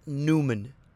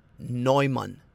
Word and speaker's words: Neumann: At number two, it's going to be another very butchered one with, now this is not Newman, okay? This is not Newman. This is going to be Neumann. Neumann. Herr Neumann.